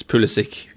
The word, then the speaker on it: Pulisic: I mean, it's what he says it is, because I mean, he's obviously of Croatian descent, so in Croatia it would be Pulisic or even Pulisic. I don't know if there's an accent on the S. – Yeah. – But you know, he's American, so he prefers an anglicised pronunciation.